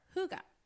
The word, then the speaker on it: hygge: is the Danish concept of hygge, or hygge. It is spelled H-Y-G-G-E, and it is a concept that is very much Scandinavian.